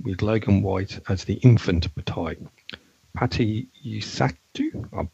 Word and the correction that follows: Yasutake: I've said that wrong. – Yes, Utaki. – There you go. As Alyssa Agoa. What do you guys think of this? I said at the start that I love this episode. So what do you guys think? – I learned long ago that when you speak Japanese names, you take each syllable at a time. So it's Yasutake. And hang on a second.